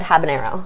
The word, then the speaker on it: Habanero: Caribbean Red Habanero. No. How is that pronunciation? – Nailed it. – Okay, good.